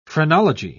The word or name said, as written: phrenology